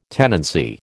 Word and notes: tenancy: American English

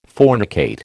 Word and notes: fornicate: American English

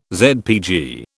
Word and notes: ZPG: British English

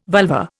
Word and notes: vulva: Indian

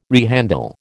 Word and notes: rehandle: American English

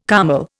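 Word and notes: kümmel: Indian

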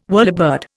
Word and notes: whirlybird: Indian

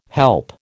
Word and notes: help: American English

